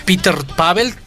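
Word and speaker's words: Petr Pavel: Porque justo el presidente de la República Checa, Peter Pavel, espero lo haya pronunciado bien. – Sí, muy por bien.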